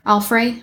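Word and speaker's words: Alfre: Alfray.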